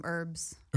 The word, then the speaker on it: herbs: So in this country, we call them herbs. – Herbs. – Why is that? we don't pronounce the H.